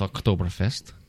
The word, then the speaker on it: Oktoberfest: Worldwide, and otherwise known as Oktoberfest. – Oh, nice pronunciation there. Yes. Good job. So we'll practice that. – Mm-hmm. – Okay. – Yeah, from the throat.